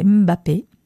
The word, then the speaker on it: Mbappé: Et puis là, voilà, notre Kylian Mbappé. Non, c'est pas comme ça, c'est Mbappé, la prononciation.